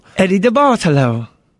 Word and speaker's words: DeBartolo: How would you pronounce Eddie DeBartolo? – Eddie DeBartolo.